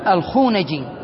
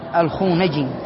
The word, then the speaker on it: الخونجي: وقال الخونجي هكذا تنطق، ماذا؟ الفو، لا لا بالنون، الخونجي.